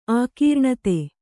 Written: ಆಕೀರ್ಣತೆ

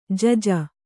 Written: ಜಜ್ಜು

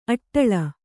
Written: ಆಡುಳ್ಳಿ

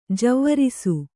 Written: ಜೀವರಸ

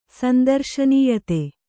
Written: ಸಂದರ್ಶನೀಯತೆ

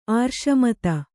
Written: ಆರ್ಷಮತ

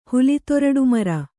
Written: ಹುಲಿತೊರಡುಮರ

